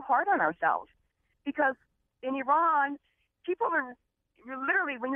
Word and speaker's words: Iran: And the first thing I realized as we were getting ready for the show is that Americans pronounce the name of that country at least three different ways. – It's Iran. – Yeah, yeah. I know. It's Iran, but you hear Iran, Iran, Iran.